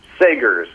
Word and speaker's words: Sagers: Sagers.